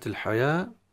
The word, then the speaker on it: الحياة: أن الواو في كلمة الحياة هي فقط رسما، لكن هي نطقا وسماعا ألف حياة حياته، فهذه من الحروف ومن المواضع التي حرف منطوق بغير ما هو مرسوم، يعني الرسم شيء والنطق شيء آخر.